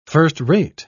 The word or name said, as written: first-rate